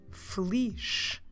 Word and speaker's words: feliz: N-A-T-A-L. Natal. And merry or happy is the word feliz. Feliz. Notice that I'm finishing the word with a soft J sound. Feliz. Como se escreve feliz? F-E-L-I-Z.